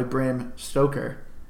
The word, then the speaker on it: Bram Stoker: By Bram Stoker.